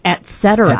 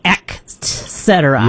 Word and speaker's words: et cetera: A lot of people say et cetera, but it's et cetera. – Et cetera. – Et cetera. Mm-hmm. Not ex cetera.